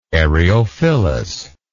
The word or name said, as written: eriophyllous